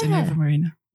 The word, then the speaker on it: Innova Marina: From Innova Marina. That's how I pronounce it. Is that correct? That's right. Thank you. Yes, Innova Marina.